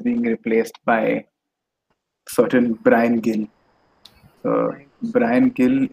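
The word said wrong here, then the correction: Gil: is a player from... – Hill. – Okay, whatever. I haven't really heard him pronounced. Heard his name pronounced. Hill.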